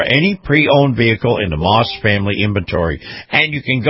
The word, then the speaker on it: Maus: Moss is spelled M-A-U-S,